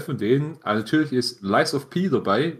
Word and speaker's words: Lies of P: Ich meine, Lies of P haben wir schon bei unserer Gamescom, unserem Gamescom-Bericht drüber gesprochen.